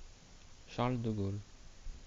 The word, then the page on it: Charles de Gaulle: prononciation